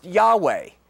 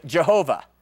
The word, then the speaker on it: Yahweh: Now, if we read G-O-D, all capitals, capital G, capital O, capital D, translated from the English Old Testament, it's the Hebrew Y-H-W-H, all right? And that's four consonants, and we're not really sure of the vowel markings, but guess that it is pronounced Yahweh, and which we have Anglicanized somewhat awkwardly to say Jehovah. Okay, Jehovah is not really a pronunciation that's correct. It's an Anglicanized form of Yahweh, and we don't even really know if Yahweh is correct,